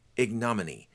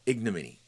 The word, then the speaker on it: ignominy: And this one is one of those words that can be pronounced in a couple of different ways. And I think that the way it's pronounced is ignominy. Ignominy is how it's pronounced.